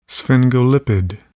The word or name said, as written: sphingolipid